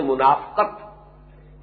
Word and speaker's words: منافقہ: اردو میں ہم اس کو تلفظ عام طور پر کرتے ہیں منافقت لیکن عربی زبان میں تو یہ گویا کہ اسی وزن کا لفظ ہے باب مفادہ کا مجاہدہ مقاتلہ منافقہ